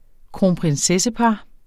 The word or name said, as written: kronprinsessepar